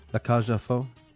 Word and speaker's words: La Cage aux Folles: How do you say A-U-X? – Ah. Isn't it La Cage aux Folles.